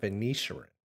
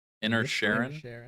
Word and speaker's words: Inisherin: And I think just to get this out of the way, a little movie that I'm going to mispronounce, The Banshees of Inisherin. – Inisherin? – Inisherin, yeah, I think. – Inisherin? Wow, I was off on both.